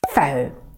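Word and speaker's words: faux: Фэу. Произносится точно так же, как слово фэу – враг.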